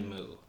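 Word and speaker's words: MØ: So it's Mo is how people pronounce it. – Mo. – Yeah. But it's actually Moo. – Moo. – Because she's Danish.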